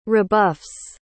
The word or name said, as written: rebuffs